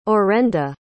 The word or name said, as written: orenda